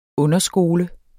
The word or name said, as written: underskole